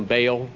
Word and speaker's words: Baal: Those two A's, you usually make a long A out of them, Baal. Like Baal worship, it's Baal in Hebrew. They do the ah-ah.